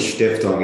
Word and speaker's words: Stiftung: is it Stiftung? How, is that the right pronunciation? – The term is Stiftung.